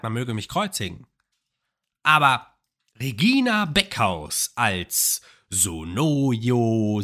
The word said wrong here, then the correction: Sonoya: Sonoya Mitsuno und dann, die Liste hatte ich in der letzten Folge noch nicht.